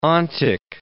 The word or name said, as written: ontic